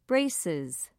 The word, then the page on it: braces: British English